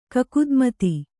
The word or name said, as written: ಕಕುದ್ಮತಿ